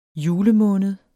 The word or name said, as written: julemåned